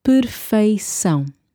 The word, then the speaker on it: perfeição: O arquétipo da perfeição, okay? I know that this is probably a difficult word because of the E and the I and then the C-A-O, nasal, soft C.